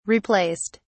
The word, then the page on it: replaced: British English